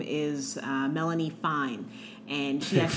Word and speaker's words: Fein: F-E-I-N. – Melanie's F-E-I-N. Fine. – Fine. Oh, you got to say it like we say, no, Melanie Fine. She's Melanie Fine.